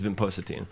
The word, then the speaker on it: vinpocetine: I try that vinprocine. How do you say it? – This is vinpocetine right here, which is in this.